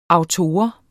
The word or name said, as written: autor